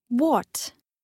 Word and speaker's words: what: Złóżcie usta tak, jakby chcielibyście ucalować w policzek bliską osobę. Zatem szykujemy się do pocałunku i wymawiamy. What?